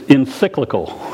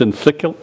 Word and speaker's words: encyclical: It's encyclical. You ever heard that word before? It's encyclical. It sounds like a bicycle.